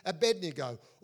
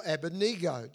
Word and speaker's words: Abednego: Daniel was named Belshazzar, Hananiah was named Shadrach, Mishael was named Meshach, and Azariah was called Abednego, or Abednego, depending on how you want to pronounce it.